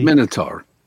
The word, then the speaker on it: Minotaur: Minotaur, am I pronouncing that correctly? – Minotaur. – Minotaur.